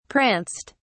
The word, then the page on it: pranced: British English